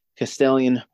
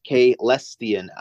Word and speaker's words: Caelestian: At any given time, only 3 battalions in operation as battle groups, with the 8th Castellian, pronounced Caelestian.